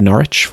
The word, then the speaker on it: Norwich: Otherwise, I would be totally out to sea when it comes to pronouncing Norwich, which looks like Norwich, for those of you who look this up online.